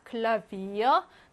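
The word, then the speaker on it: Klavier: 근데 외래어에서는요, 브로 발음합니다. 클라비어, 노벤버 이렇게요.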